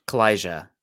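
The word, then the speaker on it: Calijah: Kalija with the J, the hard J on there. Okay. – The Portuguese J.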